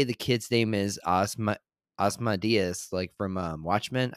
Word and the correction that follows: Ozymandias: I cannot pronounce that correctly. – Um, yeah, or Ozymandias, yeah there is a character in the Hulk comics who's like this kid who's smarter than the Hulk.